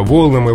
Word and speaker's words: волнам: Допустимо, волнам и волнам, волнах и волнах, волнами и волнами, но вариант с ударением на окончании предпочтительнее. То есть по волнам, на волнах.